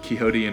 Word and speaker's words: Quixote: I don't know why they're pronounced differently, Quixote and quixotic, but here we are.